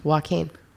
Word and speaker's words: Joaquin: Joaquin. That's how you pronounce it correctly. – I pronounce Joaquin. It W-A-K-E-E-M. Joaquin. – It does not have an M. – Right. That doesn't mean it has an N.